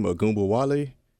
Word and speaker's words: Ogunbowale: The girl from Notre Dame, Ogunbowale, Yeah, pronouncing that correctly.